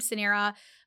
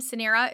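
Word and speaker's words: Suneera: And even forget that, like even my name, Sanera, it's very phonetically, like it's S-U-N-E-E-R-A. But people still would call me Serena, some like all everything in between, besides just like reading my name and like taking a minute to like say it.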